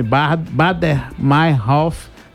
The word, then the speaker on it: Baader Meinhof: E essa história do grupo Baden, vou repetir o nome, Bader Meinhof, É porque é difícil pronunciar, mas você vai ver um nome estranho.